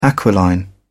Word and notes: aquiline: British English

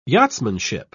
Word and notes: yachtsmanship: British English

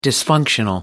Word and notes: dysfunctional: American English

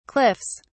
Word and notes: cliffs: British English

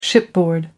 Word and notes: shipboard: American English

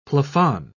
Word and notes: plafond: British English

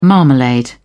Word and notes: marmalade: British English

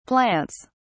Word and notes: plants: British English

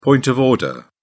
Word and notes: point of order: British English

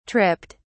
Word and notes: tripped: British English

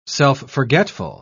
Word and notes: self-forgetful: British English

